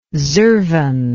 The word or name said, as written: zurvan